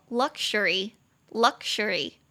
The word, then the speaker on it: luxury: 它中间的那个 x u r x u r 它发成 ksure，ksure。的音，所以它念作 luxury，luxury，豪华奢侈。